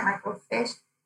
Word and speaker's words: microfiche: Microfiche? – Microfiche, yeah.